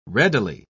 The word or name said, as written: readily